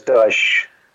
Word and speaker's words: Dyche: It's Sean Dyke, isn't it? – Don't leave it in the dark. Dyke? – No, it's Dyche. – Dyche. – Like SH, yeah. – Okay. – Yeah. – Dyche.